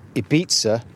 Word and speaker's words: Ibiza: My conversation with Róisín was recorded remotely towards the beginning of this month, February 2021, with me in Norfolk and Róisín in Ibiza, or Ibiza, to use the proper pronunciation, where she and her two children, along with her Italian music producer romantic partner, got stuck when lockdown 3 kicked in.